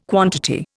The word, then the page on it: quantity: Indian